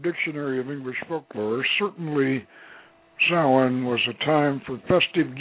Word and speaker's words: Samhain: whose original spelling was S-A-M-U-I-N, pronounced Samhain or Samhain.